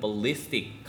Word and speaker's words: ballistic: 어쨌든 그거 관련된 건데 그래서 he went ballistic 강세가 ballie에 있습니다.